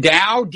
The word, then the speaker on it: Dao: Jim Dow's deputy, yeah. – Yeah, and he said, is it Dow? How do you spell it? – D-A-O, Dow, okay. Jim Dow.